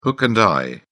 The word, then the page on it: hook and eye: British English